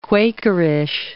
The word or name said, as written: Quakerish